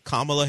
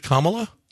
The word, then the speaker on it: Kamala: Like C-O-M-M-A? – Like if you were saying Kamala. La. Yeah. Kamala Harris. That's what I've seen. – Kamala. – Yeah. Not Kamala. Kamala Harris.